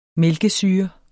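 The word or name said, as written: MÆLKESYRE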